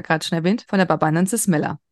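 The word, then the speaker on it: Aloe barbadensis Miller: Wir sprechen immer von der Barbanensis Miller. Marc, habe ich es richtig ausgesprochen? Ja, hast du.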